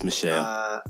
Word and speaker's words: Michel: So, I like Sony Michel. Michelle, however you pronounce his name. – It's Michelle.